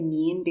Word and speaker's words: mien: Can you say that again and just say mean? Because I think you're saying the word M-I-E-N, which is just pronounced mean.